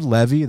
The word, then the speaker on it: Levi: Use promo code Levy. That's my last name. Spelled like Levi Jeans, L-E-V-I, not L-E-V-Y like Natan Levy, L-E-V-I like Levi Jeans.